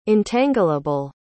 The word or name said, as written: entangleable